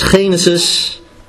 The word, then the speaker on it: Genesis: Ja, maar het is Genesis, nu zeg ik het goed, hoofdstuk 1.